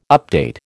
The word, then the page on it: update: American English